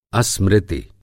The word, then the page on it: अस्मृति: Hindi